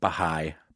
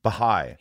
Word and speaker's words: Baha'i: Baha'i. – Baha'i.